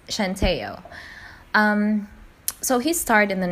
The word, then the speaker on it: Centineo: His name is Noah Centineo. That's right. His last name is not pronounced as Centineo. You know, it's softer, more relaxed, like a slow wave across a smooth, pebbled coastline.